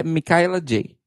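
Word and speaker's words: Michaela Jaé: Como é que é a pronúncia? É J? É, é, segundo ela própria, é Micaela J.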